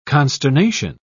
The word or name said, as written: consternation